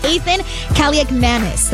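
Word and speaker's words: Athan Kaliakmanis: Washington losing quarterback Marcus Mariota to a knee injury, being replaced, by Ethan Kaliakmanis. That is correct. That is how you say it.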